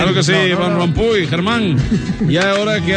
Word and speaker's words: Rompuy: S'escriu Rompuy, però es pronuncia Rompuy.